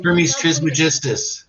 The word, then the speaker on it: Hermes Trismegistus: Hermes Trismegistus.